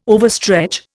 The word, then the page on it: overstretch: Indian